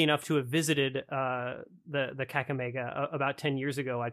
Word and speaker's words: Kakamega: the Kakamega forest. Am I pronouncing that correctly? – Yes, it's a small rainforest in Western Kenya.